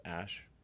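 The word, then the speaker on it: Æ: And then the AE is like pronounced ash.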